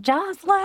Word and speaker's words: Jocelyn: Jocelyn.